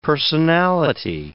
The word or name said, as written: personality